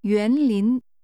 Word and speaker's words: 园林: This is pronounced with two rising tones. – 再说一次。 – 园林。